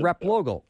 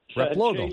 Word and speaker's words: Replogle: Replogle. I had to pause for a second, so I was freaking out a little bit. I'm thinking, say it, say it. Replogle.